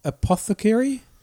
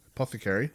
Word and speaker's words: apothecary: Apothecary. – Was he? – How do you say that? – Apothecary. – Yeah, apothecary.